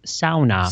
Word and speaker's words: sauna: And as they would pronounce it, sauna.